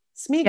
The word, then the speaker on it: Sméagol: Am I saying that right? Sméagol? – Yeah.